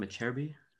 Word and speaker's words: Acerbi: Acerbi, that's how you say his name, Acerbi? – Yeah.